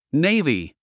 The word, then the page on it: navy: néivi; ネイビィ